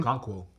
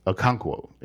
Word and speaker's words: Okonkwo: Okonkwo. – Okonkwo, yes.